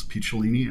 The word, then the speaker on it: Picciolini: And I was afraid to tell him because my last name is hard to pronounce, Picciolini, and it was mangled by every bully that I'd ever met. It unfortunately rhymes with weenie, so you know, you could pretty much make anything, you know, go with that.